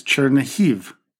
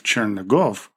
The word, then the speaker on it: Chernihiv: Our first stop is Chernihiv, a city that is in the northwestern part of Ukraine. It was previously known by its Russified name of Chernigov, but we will use its Ukrainian pronunciation.